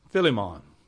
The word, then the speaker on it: Philemon: Now, I pronounce it Philemon. You may have a different pronunciation. When you are preaching in your church, you can pronounce it any way you like.